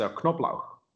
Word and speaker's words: Knoblauch: Well, what you should pronounce it like in German, and it's Knoblauch. – Say that again. Knoblauch. – Knob. And then Knoblauch. Okay. – Knoblauch. So you have to pronounce the K in front of the N.